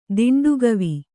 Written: ದಿಂಡುಗವಿ

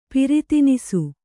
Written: ಪರಿಧಾನಿಸು

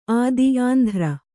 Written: ಅತ್ತಿ

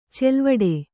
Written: ಚಲವಿಡಿ

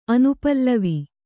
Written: ಅನುಪಲ್ಲವಿ

